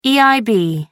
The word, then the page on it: eib: American English